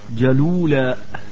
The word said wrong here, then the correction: جلولاء: تنطق الكلمة هكذا جلولاء.